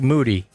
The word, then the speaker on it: Moody: Whether you have a last name like Moody, people, it rhymes with booty.